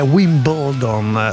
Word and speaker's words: Wimbledon: Siete imperialisti della lingua, imponete la vostra pronuncia su qualsiasi altra lingua. – Mentre invece è Wimbledon la pronuncia corretta.